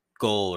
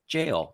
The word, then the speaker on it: Gaol: But I, I really felt like Ireland was putting up just a big old fu middle finger right to the face because jail is spelt G A O L. And so I was going to be like, oh, it's, it's goal or gale or, or something like that. And then I listened to the pronunciation and it's like jail.